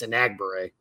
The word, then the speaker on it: Enagbare: But the practice I was at, Hollins was ahead of Enagbare, and I think it's Enagbare, I've been told, is the way that that's pronounced. So we've all been pronouncing his name wrong for over a year.